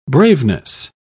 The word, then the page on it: braveness: British English